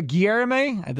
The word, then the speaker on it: Guillorme: Uh, Luis uh, Guillorme, that's how you say his name, second baseman, 293, 369 on base.